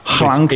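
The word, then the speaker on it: hlanc: What's the Old English pronunciation again? – Hlank. It's H-L-A-N-C.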